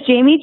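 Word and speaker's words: Jamei: Well, first of all, my name is Jamie June, as you guys all know, but it's spelled a little bit funny. It's J-A-M-E-I and then June, like the month.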